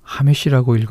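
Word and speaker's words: 하메시라고 (חמש): 배에 해당하는 히브리어가 호메시입니다. 똑같은 히브리어를 몸을 달리해서 하메시라고 읽으면 숫자 5가 됩니다.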